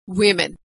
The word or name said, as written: women